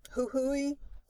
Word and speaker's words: Jujuy: So the other shawl that I really loved is called Huhui. – Is that how you pronounce it? – I believe. – Yeah, that would seem right. – Huhui, spelled J-U-J-U-Y.